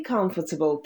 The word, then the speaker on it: comfortable: И comfortable. Там у нас comfort. А-а-а. Звук, как в словах but not cut. Comfortable. Comfort. Comfortable.